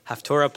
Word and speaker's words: Haftarah: Unless you happen to be where two members of the community fanatically devoted to elucidating the Haftarah with the how do you pronounce it? Haftorah plethora. Plethora.